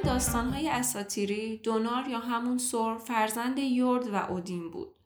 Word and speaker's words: ثور: ما ثور رو سور تلفظ میکنیم، اونم به خاطر اینه که توی زبان فارسی برخلاف عربی صدای سه محلی از اعراب نداره و همون سه تلفظ میشه.